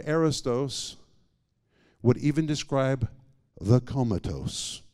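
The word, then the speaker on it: arrōstos: This is the Greek word arrestos. If you're taking notes, you spell it A-R-R-O-U-S-T-O-S, arrestos.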